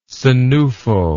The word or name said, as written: senufo